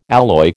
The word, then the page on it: alloy: American English